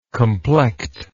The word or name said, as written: complect